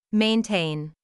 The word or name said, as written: maintain